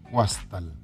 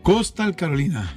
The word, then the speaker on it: Coastal Carolina: ¿Cómo se pronuncia ahí en inglés? Yo lo leo como está escrito: Guastal. – Coastal Carolina.